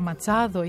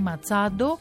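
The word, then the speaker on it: Machado: Από την άλλη, and the Nobel goes to μια γυναίκα πολιτικός, ηγέτης αντιπολίτευσης στη Βενεζουέλα, η Μαρία Κορίνα Ματσάδο ή Ματσάντο, κατά πώς θα το προφέρει κανείς.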